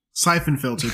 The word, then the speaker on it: Siphon Filter: Because he came from a phenomenal game series called, I'm about to butcher it, Siphon Filter. – Siphon Filter? – Siphon Filter, thank you.